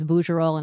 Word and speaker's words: Bougerol: But it's true that when we first started getting, you know, the occasional interview, people usually pronounce a hard G in my last name. My last name is Bougerol, and I usually get Bougerol or Buggerol, and each of those is vaguely insulting in its own way.